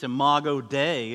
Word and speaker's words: Imago Dei: I said the word before, but this is the pronunciation. It's Imago Dei.